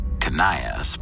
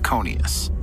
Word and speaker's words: Conius: Some news outlets pronounce Ken's last name Conius, but after consulting with the FBI for this episode, they tell us the most common pronunciation is Conius. So we're going to go with Conius.